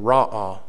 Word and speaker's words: Ra'ah: This one is pronounced, if you'll write this beside number 11, R-A-W-A-W. Accent on the first syllable, Ra'ah. R-A-W-A-W, Ra'ah, Jehovah Ra'ah.